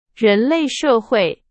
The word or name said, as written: 人类